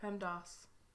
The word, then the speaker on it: PEMDAS: It's ass. A-S. – I know, but it's a long A, not a short A. PEMDAS.